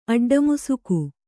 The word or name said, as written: ಅಡ್ಡಮುಸುಕು